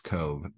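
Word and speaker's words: Cobh: Cobb or Cove, pronounced Cove, approached.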